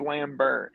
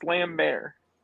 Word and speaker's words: Lambert: It's Lambert. It's Lambert. Lambert. – Lambert, like a lamb and a bear? – Like, correct. – Christopher Lambert. Yes. No, but on Highlander, they say Christopher Lambert.